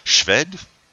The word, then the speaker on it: Szwed: He had a, a biographer who's, uh, you'll have to help me out with his surname, uh, S Z W E D. So I think that's pronounced Schved, maybe? John Schved. – Mm-hmm. So uh, his biographer. Sounds good to me.